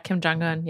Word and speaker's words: Kim Jong-un: I'd love to do a story on Kim, how do you pronounce his name? Kim Jong-un? – Kim Jong-un, yeah.